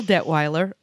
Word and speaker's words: Detweiler: And you know how I know I said that right? Because in grade school. – A long time ago. – A long, long time ago in a state far, far away. Mrs. Detweiler was the school secretary.